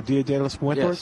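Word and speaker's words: Día de los Muertos: Marigold is the actual flower for, I always say this wrong, Dia de los Muertos. – Oh, Dia de los Muertos? – Yes, thank you.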